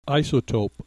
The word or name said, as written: isotope